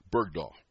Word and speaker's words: Bergdahl: Remember Berg Bogdahl? I probably pronounced the name wrong. Bergdahl, right.